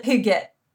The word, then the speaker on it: hygge: And hygge, am I pronouncing that right? – Well, that's Danish, so that's a little out of my expertise, since I'm Swedish, okay. But I would pronounce it hygge.